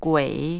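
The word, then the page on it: gui: language Chinese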